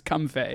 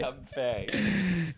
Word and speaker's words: Comfey: Comfey. It is pronounced Comfey. – Comfey.